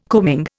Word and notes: combing: Indian

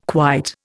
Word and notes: quite: Indian

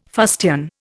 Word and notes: fustian: Indian

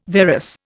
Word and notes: varus: Indian

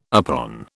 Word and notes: upon: British English

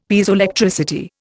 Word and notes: Piezoelectricity: Indian

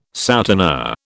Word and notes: souteneur: British English

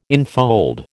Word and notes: infold: American English

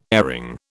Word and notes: airing: British English

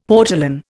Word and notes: portolan: Indian

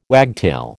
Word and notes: wagtail: American English

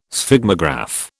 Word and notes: Sphygmograph: British English

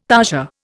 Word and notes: tarsia: Indian

